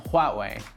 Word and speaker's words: Huawei: Together it's pronounced Huawei, as in Huawei.